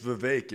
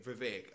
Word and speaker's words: Vivek: It's Vivek. It rhymes with Kate. – Okay, Vivek.